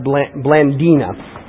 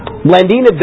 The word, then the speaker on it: Blandina: And Belinda was, actually, I think it's pronounced Blandina. Blandina